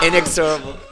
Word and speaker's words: inexorable: Inexorable. – The accent's on the second syllable.